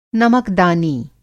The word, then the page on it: नमकदानी: نمک دانی